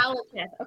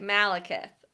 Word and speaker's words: Malekith: Malekith. Okay, hard A. Malekith. Okay.